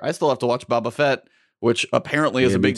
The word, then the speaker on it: Boba Fett: No, it's Boba Fett.